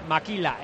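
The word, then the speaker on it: makila: Maquila, en realidad es maquila, sí. Se pronuncia maquilla. – No vaquilla, maquilla. – Con M, M-A-K-I-L-A.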